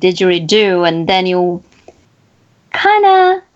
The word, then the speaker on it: didgeridoo: Cannot live without 3 things: speaking, basketball, and playing his didgeridoo. Did I pronounce it correctly? – Yeah, you pronounce it correctly, Divya.